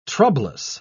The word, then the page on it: troublous: British English